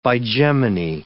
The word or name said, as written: bigeminy